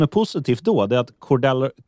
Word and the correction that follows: Cordarrelle: Cordarrell Patterson, vi är där igen med uttal, svårt att säga, Cordarrell Patterson.